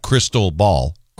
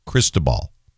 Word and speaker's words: Cristobal: Arthur, Bertha, Cristobal, not Crystal Ball, Cristobal, Dolly, Edward, not Edward, Edward, Faye, Gonzalo, Hannah, Isaias.